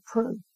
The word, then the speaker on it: Proulx: It was like Peru, but they pronounce it Pru.